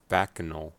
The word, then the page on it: bacchanal: American English